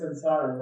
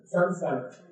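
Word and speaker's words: saṃsāra: So, you want to mispronounce it together? Samsara. There is no M before an S in Sanskrit language. Sanskrit. It's an N. Because it has to be an N. N is not allowed before S.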